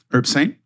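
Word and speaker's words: Herbsaint: and then Herbesaint. Am I saying that right? – Herbesaint.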